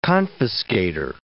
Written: confiscator

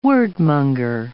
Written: wordmonger

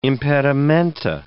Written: impedimenta

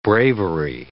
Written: bravery

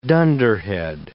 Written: dunderhead

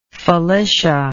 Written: Felicia

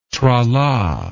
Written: trala

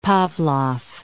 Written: Pavlov

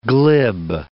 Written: glib

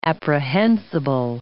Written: apprehensible

